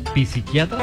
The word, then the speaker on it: psiquiatra: ¿Psiquiatra? – Mira, no se dice psiquiatra, la P no se pronuncia.